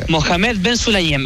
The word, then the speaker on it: Mohamed Ben Sulayem: Mohamed Ben Sulayem, el presidente de la FIA.